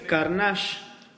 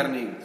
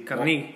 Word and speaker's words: Carnegie: Sí, Dale Carnage David ¿Ah, Carnegie, sí. – Carnegie.